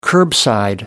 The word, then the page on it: curbside: American English